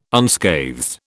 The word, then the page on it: unscathed: British English